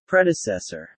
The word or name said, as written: predecessor